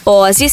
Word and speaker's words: Aussie: Ozzy se escreve Ozzy, A-U-S-S-I-E.